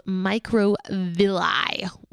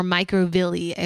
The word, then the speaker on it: microvilli: There are finger-like projections called microvilli or microvilli. I've heard it expressed in those two pronunciations.